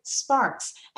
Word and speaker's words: Sparks: It's an easy name. It's Taylor Sparks, as in sparks up your life.